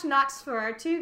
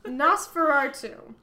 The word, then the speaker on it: Nosferatu: I don't think I'm pronouncing that correctly. – We're going to watch the Nosferatu. Is that what you said? – I'm very sorry. Oh, gosh. Nosferatu. There we go. That was better.